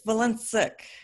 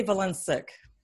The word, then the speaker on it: Valencic: It's Valencic.